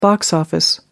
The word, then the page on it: box office: American English